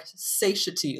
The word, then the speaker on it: satiety: Yeah, I've always said it in my head like satiety, like I'm satiated. – It's satiation, satiety, satiate.